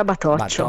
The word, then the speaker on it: Battocchio: Sì, che si scrive come il mio nome, Battocchio, ma si pronuncia Batoccio.